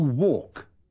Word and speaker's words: walk: Comprobará que la letra L no se pronuncia. – To walk.